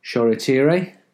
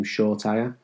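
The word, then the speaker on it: Shoretire: Well, the debut of 17-year-old forward Ajordi Shola Choretire. And Choretire is the pronunciation which was requested by his father a few weeks ago. I think everyone was calling him Shortire.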